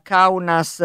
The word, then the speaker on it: Kaunas: Senta, mi sono dimenticata nella presentazione di dire che lei è semiologo ed è professore ordinario alla Kaunas, è giusta la pronuncia? – Sì, sì.